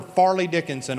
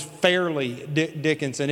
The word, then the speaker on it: Fairleigh Dickinson: I was mispronouncing the name of the school. I'd never heard of Fairleigh Dickinson. And I'm hollering for Farley Dickinson all night long till I realized that it was Fairleigh Dickinson.